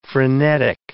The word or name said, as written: frenetic